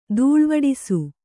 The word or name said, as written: ದೂಳ್ವಡಿಸು